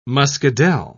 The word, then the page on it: muscadel: British English